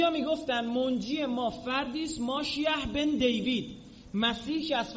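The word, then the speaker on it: ماشیح (משיח): در زبان عبری خیلی وقتا، نه همیشه، سین رو شین تلفظ میکنن، سلام رو میگن شالوم، نسا رو میگن نشاییم، موسا رو میگن مشه، مسیح رو میگن ماشیح.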